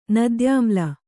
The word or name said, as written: ನದ್ಯಾಮ್ಲ